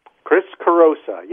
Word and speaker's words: Chris Carosa: Chris Carosa, yes.